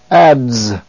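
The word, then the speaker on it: adze: It is the DZ sound, Z, as in the word ads. By the way, an ads is a tool, an axe-like tool for shaping wood. It's pronounced ads.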